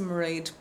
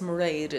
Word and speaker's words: Mairead: Well, my name is Mairead Byrne, and I usually say my name rhymes with parade so that people are confident in using it. So it's Mairead.